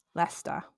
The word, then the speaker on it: Leicester: I don't know if I pronounced that right because it's not spelled like Leicester, but that's how I heard it pronounced in numerous sources. So Leicester.